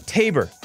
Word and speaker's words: Tabor: Spoiler alert says Tabor. Rhymes with neighbor. Tabor.